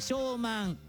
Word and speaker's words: 小満: 5 月 21 日頃、小さく満ちると書いて小満、小満で庭の雑草絶好調。